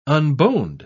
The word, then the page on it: unboned: British English